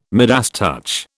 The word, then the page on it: Midas touch: British English